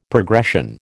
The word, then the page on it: progression: American English